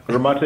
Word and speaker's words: Gramatte: Gramatte it is.